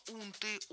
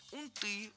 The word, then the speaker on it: унты: Гость предпочитает слово «унты», «унтах». И это его право, это литературный вариант. Но также литературным считается и вариант «унты», «унтам». То есть у нас двойная норма.